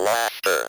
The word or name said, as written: laughter